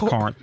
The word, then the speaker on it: Corinth: Corinth Comic Con 2023 is coming up Saturday, January 21st at the Crossroads Arena in Corinth, Mississippi. I did say that right. It's not Corinth. – It's Corinth, ma'am.